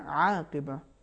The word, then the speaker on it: عاقبہ: کہیں کہیں پر انہوں نے عین کو حمزے جیسا تلفظ کیا, جیسا کہ آیت نمبر 35 میں انہوں نے پڑھا عاقبہ, صحیح ہے عاقبہ, حالانکہ انہوں نے بعد والی آیت میں اعلیٰ ہداہم اس میں آئین کا صحیح تلفظ کیا ہے.